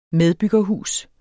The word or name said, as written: medbyggerhus